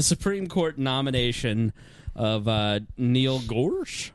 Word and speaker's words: Gorsuch: Is how do you pronounce his last name? – Yeah, somebody clarify this. – Gorsuch. – Yeah. – Gorsuch? – Gorsuch. – Okay. Gorsuch. – Gorsuch, okay. – All right. – Yeah, not Gorsh.